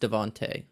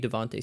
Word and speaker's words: DeVonta: And I have clarif- clarified it is spelled Devonta. It is pronounced Devonte. So from now on, he will be Devonte Smith on the podcast.